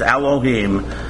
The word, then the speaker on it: Elohim: In Hebrew, the title God is pronounced Elohim.